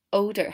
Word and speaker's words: odor: odor 它前面的 o 呢是发 o 的音，不是 or。 o odor。